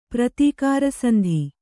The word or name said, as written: ಪ್ರತೀಕಾರಸಂಧಿ